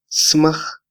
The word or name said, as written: smaj